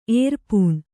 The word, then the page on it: ಏರ್ಪೂಣ್: kannada